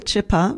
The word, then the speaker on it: Chipper: Ich weiß gar nicht, ob ich das richtig ausgesprochen habe, Chipper oder Schipper. Ich glaube Chipper, weil es mit C-H-I-P-P-E-R geschrieben wird.